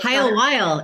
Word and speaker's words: Hiowell: Hiowell. It rhymes.